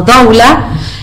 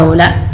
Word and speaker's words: doula: Generalmente lo pronunciamos como doula, y después ya cuando fui leyendo caí que se pronunciaba doula.